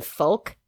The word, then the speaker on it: folk: I was so distracted by the fact that she pronounced the L in folk that I couldn't listen to for about like 3 minutes after that.